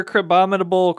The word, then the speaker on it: Crabominable: And then Crabominable. Did I say that right? – Crabominable is Bubble, Rock Smash for fast moves, charge moves, Crab Hammer, Ice Punch, Payback, and Power Up Punch.